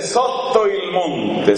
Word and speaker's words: Sotto il Monte: En italiano se dice Sotto il Monte.